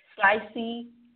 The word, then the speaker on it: Slicey: Make sure you follow that guy on Twitter at Slicey. Yep, rhymes with spicy. Slicey Ono.